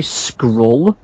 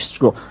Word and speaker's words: Scurll: I always say Scurll, but it's kind of spelled Skirl. – I kind of say it as if I'm just about to say Squirrel. Squirrel. – I'll stick with Scurll.